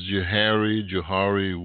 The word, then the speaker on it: Johari: came up with this concept of the Johari, Joseph, and Hari, Harry. So it's Johari window, okay? J-O-H-A-R-I. Yeah, that's how it's spelled.